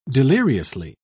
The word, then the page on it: deliriously: British English